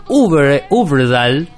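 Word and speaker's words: Øvredal: del director noruego André Uberdal. – ¿Cómo se pronuncia esa O? – Esa O es más tirando a U, es como U, O.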